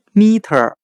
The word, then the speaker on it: meter: 我们这样来记忆这个单词 meter，米中的 m e 读咪，很像汉语的米的发音。